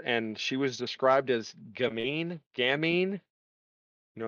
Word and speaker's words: gamine: G-A-M-I-N-E. Gamine. It's like playful. I don't know. It's like, it's kind of a weird description. I don't know why. – Is a slim, often boyish, elegant young woman who is or is perceived to be mischievous, teasing, or sexually appealing. It's a French word.